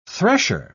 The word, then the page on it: thresher: British English